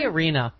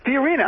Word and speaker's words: Fiorina: How do you pronounce it? – Fiorina. Who knows? – Fiorina. I'm half Italian. I should know that. You're right, Fiorina.